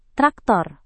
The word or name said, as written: traktor